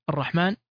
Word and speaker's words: الرحمن: أيضا سيأتي معنا لفظ الرحمن، وكذلك يثبت فيه ألف صغير بعد الميم، وإن كانت محذوفة ولا تكتب خطا نظرا لكثرة استعمال هذه الكلمة، إلا أن الطالب والطالبة مطالبان بإثبات هذه الألف بعد الميم من كلمة الرحمن أثناء تهجي الكلمة، وكذلك عند النطق بها كاملة.